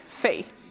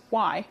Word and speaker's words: whi: And one of the examples is W-H-I. And to me, I'd go immediately, fi, because of how we pronounce Māori now. But no, he was actually trying to write down water, why. But he was doing it that, with that British kind of why, like you'd spell white.